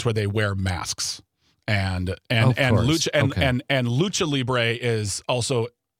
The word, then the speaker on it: Lucha Libre: Yeah, so it's Lucha Libre. Lucha. It's pronounced Lucha Libre.